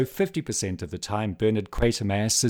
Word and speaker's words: Quatermass: And note the pronunciation, everyone. I have egg on my face. I have been lambasting my poor American friends for mispronouncing Quatermass's name. Now, about 50% of the time in this film, they do say Quatermass, but they also say Quatermass.